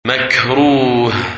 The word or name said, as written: Makruh